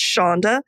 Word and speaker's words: Sandhya: I feel like we could do an entire episode just on names, but my name is pronounced Shonda, and it's spelled S-A-N-D-H-Y-A, and the last name is Jha, J-H-A.